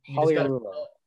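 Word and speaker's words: Pagliarulo: Yeah, no, it's just silent G. That's all you got to remember. – Poliarulo.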